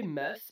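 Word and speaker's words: Mes: So I say Mess. It's basically just like the word mess. Like, I am a mess, M-E-S-S, but one less S.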